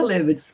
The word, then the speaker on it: Hlebetz: Sim, Cláudia Levitz, correto.